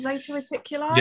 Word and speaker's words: Zeta Reticuli: Is that how it's pronounced? Zeta Reticuli? – Yeah, Zeta Reticuli.